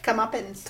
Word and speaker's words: Comeuppance: Come up. How do you say it? – Comeuppance. – Comeuppance. – Like come muffins.